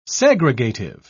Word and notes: segregative: British English

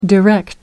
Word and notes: direct: American English

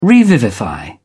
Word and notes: revivify: British English

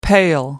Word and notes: pail: American English

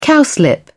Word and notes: cowslip: British English

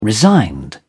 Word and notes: resigned: British English

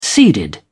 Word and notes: seeded: British English

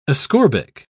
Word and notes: ascorbic: British English

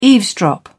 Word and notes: eavesdrop: British English

